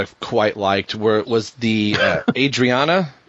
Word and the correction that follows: Ariana: stuff? – Ariana. – Yeah. Ariana. See, I always say it wrong.